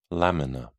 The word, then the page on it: lamina: British English